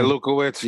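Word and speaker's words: Lewkowicz: Samson Lekowitz, I think that's how you pronounce it. Someone write in KOTB boxing. – Yeah, Lukowitz. Yeah.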